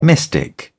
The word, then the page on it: mystic: British English